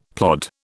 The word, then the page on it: plod: British English